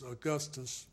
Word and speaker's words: Augustus: Douglas Augustus Miller.